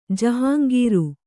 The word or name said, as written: ಜಹಂಗೀರು